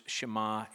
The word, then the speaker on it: Shema: known to the Hebrews as the Shema, which is based on the pronunciation of the first word here in English, Shema in Hebrew.